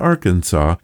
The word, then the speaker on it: Arkansas: In 1881, the Arkansas Historical Society concluded that both Kansas and Arkansas have roots in similar Indian tribe names, but that Kansas chose to follow the standard English pronunciation marked by the hard A sound in can and vocalizing the final S, while Arkansas stuck with the original French pronunciation, which kept that final S silent.